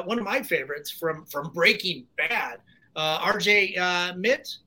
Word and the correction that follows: Mitte: am I saying his last name right? – RJ Mitty. Okay. I've been mispronouncing it for all these years.